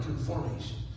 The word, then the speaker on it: conformation: Your destination may be heaven, but your destiny is confirmation. – Amen. – Not confirm, conformation.